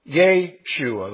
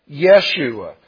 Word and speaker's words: Yeshua: Yeshua, the long E. Somewhere along the line now that's gotten shortened to the short E, Yeshua.